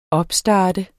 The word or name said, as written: opstart